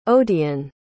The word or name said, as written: odiane